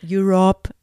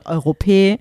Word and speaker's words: Europe: war ja gerade so Final Countdown, Europe. – Ah. – Da wusste ich natürlich nicht, wie es ausgesprochen wird. Das hieß dann Europä.